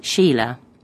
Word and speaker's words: Sheila: My first name's Sheila. S-H-E-I-L-A. And I spell my second name with a Y. That's W-Y-N-F-O-R-D.